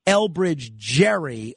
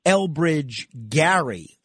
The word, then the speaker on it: Elbridge Gerry: It's named after a fella that everybody calls Elbridge Gerry, although he pronounced his name Elbridge Gary.